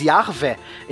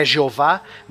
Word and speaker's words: Yahvé: Isso é muito interessante porque até esse ponto, os deuses, tanto Yahvé, por sinal, ouvintes, Yahvé é a pronúncia hebraica de Javé, tá? Então tô, é a pronúncia correta da, da, do nome de Deus, né? Não é Jeová nem Javé, é Yahvé, né? Tem um, um um catarro ali no fundo da garganta para falar.